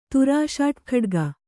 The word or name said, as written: ತುರಾಷಾಟ್ಖಡ್ಗ